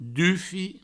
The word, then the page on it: dufi: Provençal